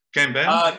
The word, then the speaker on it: Kanban: Kanban.